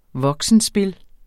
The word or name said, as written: voksenspil